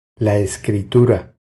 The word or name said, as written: La escritura